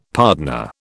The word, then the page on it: pardoner: British English